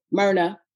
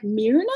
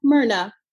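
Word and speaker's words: Mirna: Okay, so well, because you can say my name in Spanish as Myrna Valerio, or, you know, the black way, Myrna. Right. for some reason, people look at my name and they're like, Myrna? And I'm like, no, Myrna. – I just want people to know it's spelled M-I-R.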